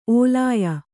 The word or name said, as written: ಒಳಾಯ